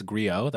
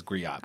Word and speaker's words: griot: I didn't know what it's griot. That's how it's pronounced. I thought it was griot, but I didn't know what that was until a year ago.